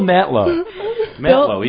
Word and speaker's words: Mantlo: Bill Mantlo. He's from New York. – Okay. – The T is sort of silent.